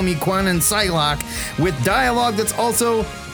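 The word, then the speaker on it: Kwannon: Now, Free Quanon wants to be called Psylocke. – I think it's pronounced Quanon. – Whatever.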